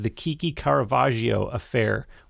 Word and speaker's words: The Kiki Caravaggio Affair: The title of the EP, if I'm pronouncing this correctly, is The Kiki Caravaggio Affair. Where does that name come from? – Correct.